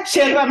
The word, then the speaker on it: yerba: Yerba mate, por eso se dice yerba, porque se escribe con Y.